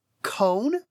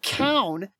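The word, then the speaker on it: Kown: There's a series that I really like by Jen Lyons called Chorus of Dragons, where a character in book two, the reader calls him Cone. It's K-O-W-N. And then in the next book, they pronounce it Cown.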